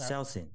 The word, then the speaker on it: Saosin: The band is called Selsin. – Okay. – I don't know if I'm pronouncing it right. I will write it down for you. – Okay. – Saosin. Saosin.